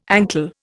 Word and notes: ankle: Indian

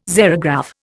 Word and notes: xerograph: Indian

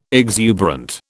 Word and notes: exuberant: British English

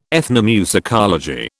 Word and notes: ethnomusicology: British English

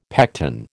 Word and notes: pecten: American English

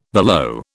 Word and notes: below: British English

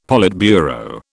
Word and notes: politburo: British English

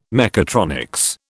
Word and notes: mechatronics: British English

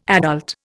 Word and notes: adult: Indian

